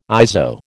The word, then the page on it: ISO: American English